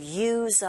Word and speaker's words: use: Not only do the people use umbrellas, 동사 use는 쓰가 아니라 z로 발음된다는 거 아시죠?